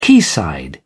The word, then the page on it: quayside: British English